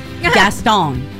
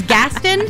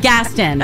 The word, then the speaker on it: Gaston: I always say the name of this town wrong because I always want to say it like the Disney villain, Gaston. But it's not Gaston. It's Gaston? Yeah. Gaston. Robert knows.